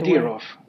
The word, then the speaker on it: Kadyrov: I mean, it was a very, very violent operation, and eventually he turned to this guy, Ramzan Kadyrov. Am I pronouncing that at all right, by Kadyrov. – The way? – Kadyrov. It's Ramzan Kadyrov, yeah.